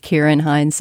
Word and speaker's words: Ciaran Hinds: I'm getting a word from our producer, Daniel Schrader, that it's pronounced Ciaran Hinds. So sorry about that, Irish people out there.